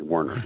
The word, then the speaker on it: Werner: It's Warner, not Warner, correct? – Yes, okay. Like the ladders in the trucks, but we're the different family, Werner.